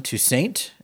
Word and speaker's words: Toussaint: As well as Allen Toussaint.